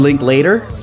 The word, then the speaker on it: Linklater: Richard Linklater? – Later, like, yes. – We'll link up later? – Yeah, you got it.